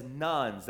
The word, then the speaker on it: nones: I know that those people that categorize themselves as nuns, that's spelled N-O-N-E-S, not N-U-N-S, but N-O-N-E-S, those are the people that categorize or self-identify as atheists or as agnostics.